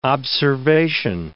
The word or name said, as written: observation